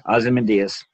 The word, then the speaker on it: Ozymandias: How do you say Ozymandias? – That's right. – Okay, Ozymandias. – I always butchered that.